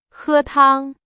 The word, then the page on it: 汤: Chinese